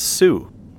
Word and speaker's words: Sault: Sault, or may I should pronounce it Sault, since it's S-A-U-L-T, off the first of two untitled albums that came out this year.